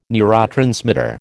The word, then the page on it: neurotransmitter: American English